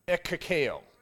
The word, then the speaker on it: ekakeo: But it's ekakeo. Ekakeo. Is that right? Ekakeo. Yes. Okay, there I am.